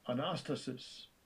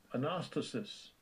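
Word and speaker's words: Anastasis (ἀνάστασις): Strong's G, 386, Anastasis. Anastasis.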